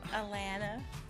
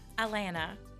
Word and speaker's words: Alana: Anyways, but anyways, so Elena, wait, no. – Alana.